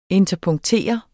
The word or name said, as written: interpunktere